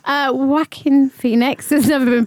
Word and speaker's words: Joaquin Phoenix: How did you say his name? – Joaquin Phoenix. – That'll do. That's correct.